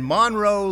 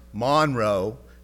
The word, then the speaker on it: Monroe: Newton grew up in Monroe, Louisiana, one of the places I lived when I was a kid. I don't know why it's pronounced Monroe, not Monroe, but that's the way it is in Louisiana.